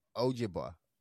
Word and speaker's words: Ojibwa: Ojibwa. Did I say that right? – Yes.